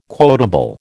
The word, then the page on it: quotable: American English